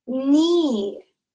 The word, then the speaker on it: knee: We have the word knee. Knee. – K N E E. So the K is silent, and this is a part of your leg.